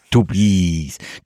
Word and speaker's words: Tubize: Wir haben jetzt in Tubize, das wird bestimmt anders ausgesprochen. – Tubize.